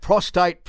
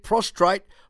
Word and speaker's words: prostate: Although I will be pronouncing it prostrate from time to time, and maybe you can text in and tell me when I say prostrate instead of prostate as well.